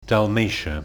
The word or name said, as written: Dalmatia